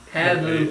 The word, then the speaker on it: Paavu: Pavu. – Pavu? – Pavu. – P-A-A-V-U. – Hang on. Spell it. – P-A-A-V-U.